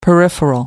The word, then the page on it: peripheral: American English